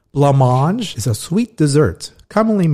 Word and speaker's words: Blancmange: So now we move to number 32, Blancmange, Living on the Ceiling.